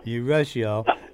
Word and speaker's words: Urushiol: That Eucerol, how do you pronounce that? – Urushiol.